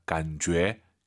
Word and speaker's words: 感觉: 感觉, 이 제이유이는요 주애가 아니에요. 너의 죄입니다. 感觉, 이렇게 발음하시기 바랍니다.